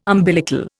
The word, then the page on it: umbilical: Indian